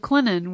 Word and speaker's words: Clinton: Mayor Randy Randall from the city of Clinton, where the tea is sweet and the tea is silent.